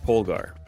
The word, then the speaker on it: Polgar: I pronounce it Polgar. But it's a little bit similar. Of a mouth. It's like a mouthful for some people. Some people just leave out the L. So they'll say Polgar.